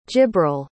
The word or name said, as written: jibril